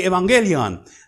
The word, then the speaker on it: evangelion: The good news, they would say evangelion. That's how they would pronounce it.